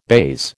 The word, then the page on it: baize: American English